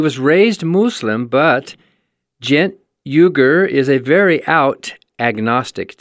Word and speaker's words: Uygur: Is that how you say his name? – I think so. – Uygur, U-Y-G-U-R. Gent Uygur.